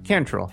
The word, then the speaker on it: Cantrell: Cantrell.